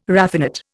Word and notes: raffinate: Indian

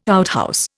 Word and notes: outhouse: Indian

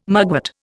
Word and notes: mugwort: Indian

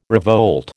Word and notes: revolt: American English

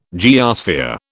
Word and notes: geosphere: British English

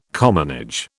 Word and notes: commonage: British English

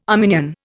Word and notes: Arminian: Indian

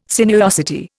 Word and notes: sinuosity: Indian

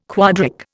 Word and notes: quadric: Indian

